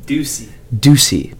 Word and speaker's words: Ducey: Is it Ducey? How do you pronounce your surname? – Ducey. – But it's spelled D-U-C-E-Y. – D-E what? – D-U-C-E-Y.